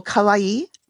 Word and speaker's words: kawaii: Oh, kawaii. So that's K-A-W-A-I-I, kawaii.